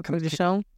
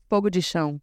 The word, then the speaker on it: Fogo de Chão: Yeah, Fogo de Chão. – Can you pronounce it better for me? – Fogo de Chão.